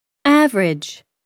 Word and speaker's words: average: The vowel immediately after a stressed syllable is sometimes not pronounced. – Average.